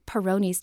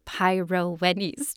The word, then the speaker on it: Peyronie's: It's called Peyronie's disease, not Peyronie's disease.